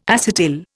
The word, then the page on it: acetyl: Indian